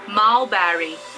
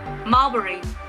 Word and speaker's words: Mulberry: Mulberry. Is that right? Obviously not. The correct one is Mulberry.